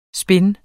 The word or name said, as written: spind